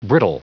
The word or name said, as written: brittle